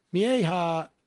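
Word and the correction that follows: Mejía: That's Ana Lilia Mejia. – Mejia. – Is the one person who can pronounce it in this office.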